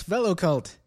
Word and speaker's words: Velocult: My takeaway was how to pronounce Velocult. So it's Vel-O-Cult. – Rhymes with Jell-O-Cult.